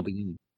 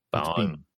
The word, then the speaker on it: Biehn: Michael Biehn. – Biehn.